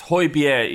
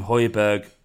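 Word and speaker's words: Højbjerg: It's Hoybier is the correct way to pronounce it. And this is from someone who doesn't pronounce any. I can't even. My dad's name's David, and I fuck it up 30% of the time. But I know his name's Hoybier, but people call him Hoiberg.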